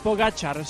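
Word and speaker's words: Pogačar: Pogacar, sí señor Pereiro.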